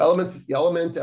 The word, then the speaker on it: LMNT: So Element, it's spelled L-M-N-T.